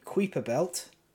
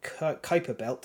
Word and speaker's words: Kuiper: Before I hand off to Alexis, Planet X is a theoretical planet in orbit in the Kuiper Belt, or Kuiper Belt, depending whether you're British or American. That's the two pronunciations.